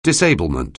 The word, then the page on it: disablement: anglais